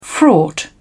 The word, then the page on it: fraught: British English